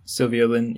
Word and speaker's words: Sylvia Lin: Sylvia Lin, yep.